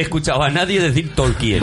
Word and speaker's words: Tolkien: Vamos a ver, yo siempre he dicho Tolkien con, pronunciando la E. ¿Tolkien? Tolkien, sí.